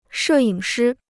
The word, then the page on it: 摄影师: shè yǐng shī